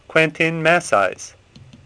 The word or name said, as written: Massys